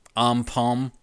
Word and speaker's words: AMPM: It's spelled AMPM, Am Palm.